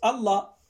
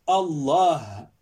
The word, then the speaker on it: Allah: Déjà, si tu l'as bien prononcé avec le « ha » fi akhirihi, avec le A à la fin, parce que beaucoup prononcent Allah. Non, il y a un H à la fin. Allah.